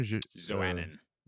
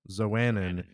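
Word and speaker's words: Xoanon: He interacts with the god, which, how do you say the name? – Zoannon. – Zoannon. – Yeah.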